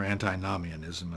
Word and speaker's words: antinomianism: There are at least two meanings of antinomianism, or antinomianism, as it's sometimes pronounced, which means literally anti-law.